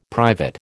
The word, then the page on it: private: American English